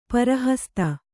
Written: ಪರಿಹಸಿತ

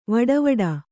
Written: ವಡವಡ

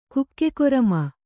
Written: ಕುಕ್ಕೆಕೊರಮ

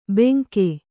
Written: ಬೆಂಕೆ

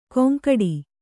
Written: ಗೊಂಗಡಿ

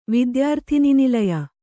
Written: ವಿದ್ಯಾರ್ಥಿನಿನಿಲಯ

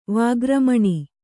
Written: ವಾಗ್ರಮಣ

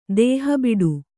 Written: ದೇಹಬಿಡು

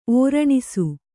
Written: ಓರಣಿಸು

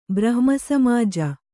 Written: ಬ್ರಹ್ಮಸಮಾಜ

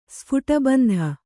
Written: ಸ್ಫುಟಬಂಧ

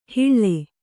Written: ಹಿಳ್ಳೆ

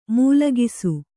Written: ಮೂಲಗಿಸು

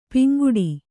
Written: ಪಿಂಗಡೆ

